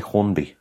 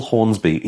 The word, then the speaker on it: Hornsby: I always get his surname wrong. I always say Hornby. Russell Hornsby, he's from Grimm.